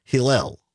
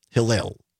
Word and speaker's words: Hillel: It is pronounced Hillel. Hillel. So I apologize for the mispronunciation on that.